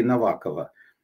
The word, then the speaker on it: Новакова: Новакова или Новакова? По-моему, Новакова. Чешские ударения.